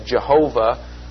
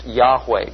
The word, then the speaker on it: Yahweh: The answer to that is that this is the personal name of God, what is sometimes misread as Jehovah, probably better pronounced Yahweh.